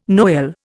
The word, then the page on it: Noel: Indian